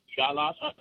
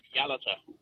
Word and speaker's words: Yalata: Just before I try and give an answer, you pronounce the town on the Nullarbor as Yalata. It's actually pronounced Yalata.